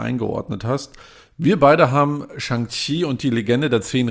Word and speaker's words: Shang-Chi: Und das wird so phonetisch geschrieben für die Amerikaner oder Europäer Chi. Und deshalb ist Shang-Chi, spricht man so aus in Amerika oder sowas. Und in China sagt man Shang-Chi, aber wenn man da schreibt Shang-Qi, weiß man nicht, wie man das ausspricht.